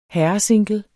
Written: herresingle

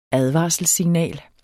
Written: ADVARSELSSIGNALET

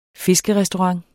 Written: fiskerestaurant

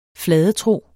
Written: fladetro

